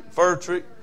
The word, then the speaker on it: Furtrick: He made Futrick look like a kid in kindergarten. Amen. I did say that. Did I say his name right? Futrick? Furtrick? Furtrick. Furtrick.